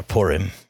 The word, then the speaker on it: Purim: and the Feast of Purim, and basically, or Purim, I should say, if you want me to say it properly, not English, is that it gets another feast, an Israelite feast, which we'll talk about later, gets implemented.